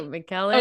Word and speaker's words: McKellen: McKellen.